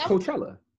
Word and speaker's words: Coachella: It's Coachella. – I'm going to say it wrong. – Say it with me. – Coachella. – Coachella. You saying controller.